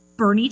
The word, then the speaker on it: Boerne: Bernie, Texas. – Bernie, Texas. All right. – Which is around us. And it's spelled like Born, B-O-E-R-N-E. But I guess it said Bernie, Texas.